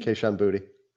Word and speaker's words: Kayshon Boutte: And this might be, is it Kayshon Boutte? Is that how you say his name? Boutte? – Kayshon Boutte.